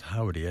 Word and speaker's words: Hovvdy: I was going to hear something from, it's Howdy, I guess, but it's spelled H-O-V-V-D-Y, which probably many of you know, but I didn't know that the pronunciation was Howdy. I thought you pronounced the V's, but there you go.